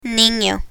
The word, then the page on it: nino: Spanish